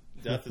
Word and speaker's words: Deth: I think I'm gonna stick with the Death. Death is spelled DETH, by the way, for anyone listening.